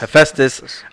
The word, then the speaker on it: Hephaestus: I don't even know how to pronounce Hephaestus. – That. Hephaestus.